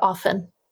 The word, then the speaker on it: often: I hate it when people pronounce the T in the word often.